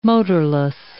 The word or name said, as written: motorless